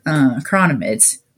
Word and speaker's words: chironomid: It's been a while since I've caught a big fish on the strip, but usually I do fish the indicator with midges or chironomids, which is the correct way to say it.